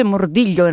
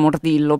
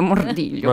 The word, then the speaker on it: Mordillo: Si legge Mordiglio in realtà, per cui è per quello che la gente sì. Si... sì, sì, in Italia diciamo Mordillo, però in realtà sarebbe Mordiglio.